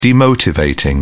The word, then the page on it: demotivating: British English